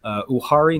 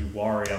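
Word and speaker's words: Uwharrie: asks, there have been sightings in Uhari National Forest, North Carolina. Any plans to investigate there? – Awesome. Thank you. I think it's pronounced the Uhari. I'm not certain.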